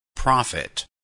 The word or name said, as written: profit